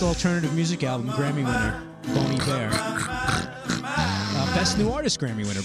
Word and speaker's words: Bon Iver: It's pronounced Bon Iver. – Oh, it's spelled B-O-N, and the second name is I-V-E-R.